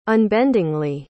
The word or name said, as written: unbendingly